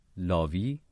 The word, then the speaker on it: لاوی: یکی از شنوندگان ما آقای لاوی، درست تلفظ میکنم؟ بله، روی خط ارتباطی ما هستن.